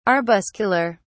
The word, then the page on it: arbuscular: British English